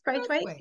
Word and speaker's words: Braithwaite: Yes, Braithwaite.